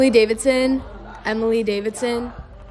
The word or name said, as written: Emily Davidson